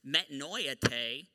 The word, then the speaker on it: metanoeite: But what we as English readers of this verse don't see is that the word repent in this verse, which is pronounced in the Greek as metanoeite, is actually to be interpreted as a continuous act of repentance.